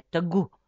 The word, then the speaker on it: Тагу: «Питате какво значи Тагу?» С ударение върху У се произнася Тагу.